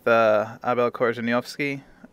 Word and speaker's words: Korzeniowski: So I'm speaking with Abel Korzeniowski. Am I saying that correct? Oh, it's the best pronunciation so far.